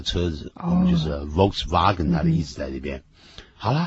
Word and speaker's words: Volkswagen: 其实不是念的 Volkswagen，是就是上面那个车子啊，那个型号是 VW 这两个英文字母，有没有？它应该念成 Volkswagen。